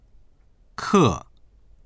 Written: ke4

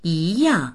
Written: yi2yang4

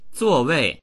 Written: zuo4wei4

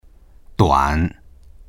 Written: duan3

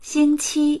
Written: xing1qi1

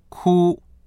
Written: ku1

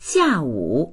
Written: xia4wu3

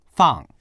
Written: fang4

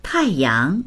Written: tai4yang2

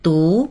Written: du2

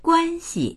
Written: guan1xi

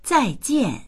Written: zai4jian4

